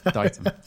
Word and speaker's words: Ditum: So it's called The Mispronounced Item because people mispronounce Ditum.